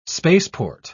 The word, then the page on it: spaceport: British English